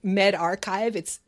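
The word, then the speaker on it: medRxiv: It hasn't been, as you say, peer reviewed, but it's on what's called a preprint server, which is called MedArchive. It's spelled Med, capital R, XIV, that does screen its research before it's posted, even though they don't do a formal peer review.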